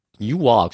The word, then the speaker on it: Newark: Newark spricht man das aus, oder? Also das, was wie New York klingt, aber eigentlich anders geschrieben wird, Leute, da draußen.